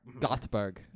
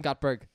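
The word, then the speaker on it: Göteborg: There's Madame Gottlieb. – Nope. – What is it? – It's Iceland, not Icelandic. It's Swedish, and I can't pronounce it. Gothberg. Yep, that one. – Madame Gottberg.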